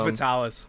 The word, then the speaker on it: Vitalis: Like Vitalis, or is it Vitalis? I don't even know how to pronounce it. But it's Vitalis.